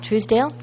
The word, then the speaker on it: Truesdale: My name is Carol Truesdale.